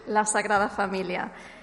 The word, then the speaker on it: La Sagrada Familia: That's how you say it, La Sagrada Familia.